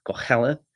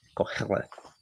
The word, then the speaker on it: Koheleth: Especially considering the Hebrew have a very harsh H there, Koheleth, as opposed to Koheleth, which is how we normally pronounce it, Koheleth.